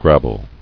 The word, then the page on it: Grabble: grab·ble